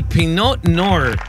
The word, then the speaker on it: Pinot Noir: Hoy es el Día Nacional del Pinot Noir.